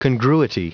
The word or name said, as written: congruity